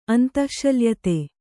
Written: ಅಂತಃಶಲ್ಯತೆ

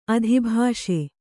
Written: ಅಧಿಭಾಷೆ

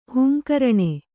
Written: ಹಿಂಗರಣೆ

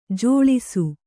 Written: ಝಳಿಸು

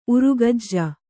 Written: ಉರಗೆಜ್ಜೆ

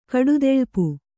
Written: ಕಡುದೆಳ್ಪು

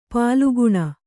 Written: ಪಾಲಘ್ನ

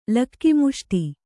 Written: ಲಕ್ಕಿಮುಷ್ಟಿ

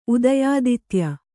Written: ಉದಯಾದಿತ್ಯ

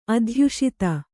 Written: ಅಧ್ಯುಷಿತ

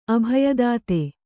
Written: ಅಭಯದಾತೆ

